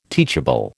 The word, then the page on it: teachable: American English